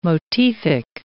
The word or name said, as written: motific